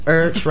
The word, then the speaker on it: Urch: my name is Kaki, like the pants, Urch, rhymes with church.